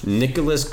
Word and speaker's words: Nicholas: The three players are Nick Olas Castellanos. – Nicholas Castellanos. – That's better pronounced.